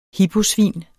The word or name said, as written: hiposvin